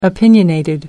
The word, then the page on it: opinionated: American English